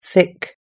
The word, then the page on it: thick: American English